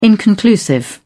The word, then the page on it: inconclusive: British English